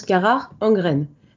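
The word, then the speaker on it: Carach Angren: Normalement, toujours selon ce même appendice E, le nom du groupe qui nous intéresse aujourd'hui se prononce Karakangren, avec le type de son CH qu'affectionnent les Allemands ou les Écossais. Nart, Lore, tout ça. Franchement, ce n'est ni facile ni marrant à prononcer et surtout, on n'a vraiment pas l'habitude. Alors, nous allons nous en tenir à Karakangren, comme les Français qui parleraient du Loch Ness.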